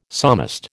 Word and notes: psalmist: American English

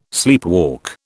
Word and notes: sleepwalk: British English

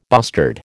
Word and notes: bustard: American English

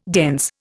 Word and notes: dense: Indian

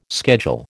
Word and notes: schedule: American English

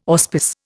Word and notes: auspice: Indian

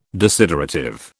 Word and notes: desiderative: British English

